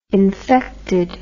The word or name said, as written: infected